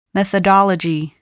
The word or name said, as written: methodology